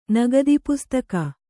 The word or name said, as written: ನಗದಿಪುಸ್ತಕ